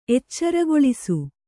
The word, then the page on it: ಎಚ್ಚರುಗೊಳಿಸು: eccarugoḷisu